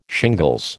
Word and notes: shingles: American English

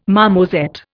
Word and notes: marmoset: Indian

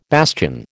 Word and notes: bastion: American English

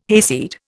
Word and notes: hayseed: Indian

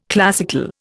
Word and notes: classical: Indian

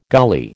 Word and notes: gully: American English

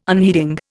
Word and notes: unheeding: Indian

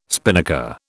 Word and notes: spinnaker: British English

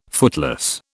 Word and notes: footloose: British English

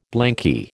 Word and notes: blanky: American English

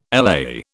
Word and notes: LA: British English

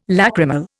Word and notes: lacrimal: Indian

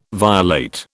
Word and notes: violate: British English

